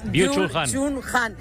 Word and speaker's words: Byung-Chul Han: Byung-Chul Han.